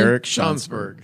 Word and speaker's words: Eric Schansberg: Eric Schansberg.